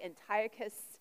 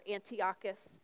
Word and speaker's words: Antiochus: Antiochus or Antiochus. It's pronounced different ways by different people.